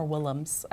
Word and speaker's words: Willems: We've read some Mo Williams before. More Willems, I think, is how you say his name.